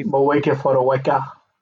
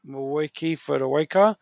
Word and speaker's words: Moeaki Fotuaika: Moeaki Fotuaika. Moeaki Fotuaika from Titans as well.